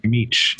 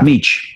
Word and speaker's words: Mech: Mech? Is that how you pronounce it? Mech. Yeah. – Mech.